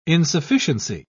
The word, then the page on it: insufficiency: British English